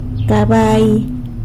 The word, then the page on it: cabai: sanlucasquiavini